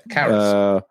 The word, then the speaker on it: Karras: What place does Father Karras, is that how you say his name? Karras? – Yeah, Karras.